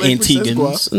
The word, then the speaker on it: Antigua: Yeah, there's no gua. – My fault, Antigua. My fault, my Antiguans. – No, it's not. We're actually a British colony, and the way that you pronounce it properly is Antigua.